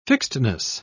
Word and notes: fixedness: British English

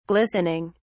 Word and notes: glistening: British English

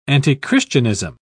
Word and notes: antichristianism: British English

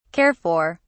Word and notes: care for: British English

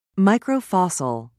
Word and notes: microfossil: British English

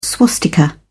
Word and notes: swastika: British English

